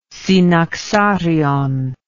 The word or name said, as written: synaxarion